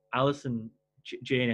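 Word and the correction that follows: Allison Janney: I think it's pronounced Alison Janney, so I'll just use that.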